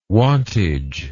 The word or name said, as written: wantage